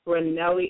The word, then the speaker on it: Ranelli: Ranelli is perfect.